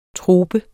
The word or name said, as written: troper